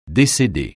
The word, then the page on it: décéder: French